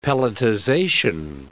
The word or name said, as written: pelletization